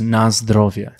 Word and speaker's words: na zdrowie: So I don't know if you remember from before, but we taught you how to say the Polish way of saying cheers. – Yes. – Which is na zdrowie. – Yes, nice driveway.